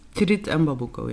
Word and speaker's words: Tirit en Babuko: Dit komt samen in het project Tirit en Babuko aan tafel. Zeg ik dat goed? – Tirit en Babuko, ja.